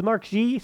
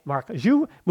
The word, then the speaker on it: Marc Guiu: Marc Guiu on for Fermin Lopez, and I was told it was Mark G, so this is a pronunciation correction. It is Marc Guiu moving forward. That is the Catalan pronunciation, so that's what we're going with.